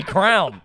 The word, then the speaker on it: crayon: I say crown. – Crown. How the fuck do you get there? Crayon's better than crown. – I don't know. My dad was dumb. – There's three different pronunciations of that same thing at this one table.